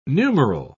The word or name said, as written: numeral